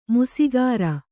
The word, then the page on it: ಮೀಸೆಗಾರ: mīsegāra